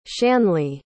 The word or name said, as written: shanley